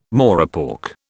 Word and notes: morepork: British English

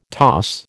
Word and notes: toss: American English

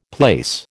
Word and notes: plaice: American English

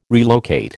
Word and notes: relocate: American English

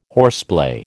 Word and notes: horseplay: American English

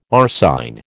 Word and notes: arsine: American English

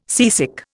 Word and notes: seasick: Indian

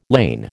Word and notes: lain: American English

